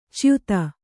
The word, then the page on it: ಚ್ಯುತ: cyuta